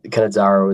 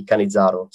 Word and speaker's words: Cannizzaro: No, look, I go with Cannizzaro as well. But I believe if you were in Italy and you were saying it, it might be Cannizzaro. So, but for the purposes of today, I'm happy with the Australian pronunciation, which is what I use, by the way.